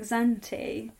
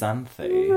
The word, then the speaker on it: Xanthi: Is it pronounced Xanthi? I always thought it was pronounced like Xanti. – I think it's Xanthi.